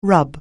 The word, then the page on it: rub: anglais